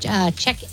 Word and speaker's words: Czechia: Yes, they beat Czechia. Is that how you say it? – Yeah.